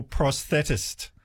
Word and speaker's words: prosthetist: My wife is a dental prosthetist. – Well, you did well pronouncing that, by the way.